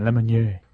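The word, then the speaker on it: Lemonier: Have we ever decided like whether name. It was actually Lemonnier? – No. – That's the proper way to pronounce it. – Lemonnier. Lemonnier. – No. You had it first time. Lemonnier.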